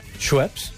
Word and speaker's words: Schweppes: En aquells temps, l'empresa de begudes Esbepes. – Perdó? – Schweppes.